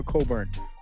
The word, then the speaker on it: Cockburn: Coburn. – Yeah. – Spelled C-O-C-K-B-U-R-N, and his name is somehow Coburn.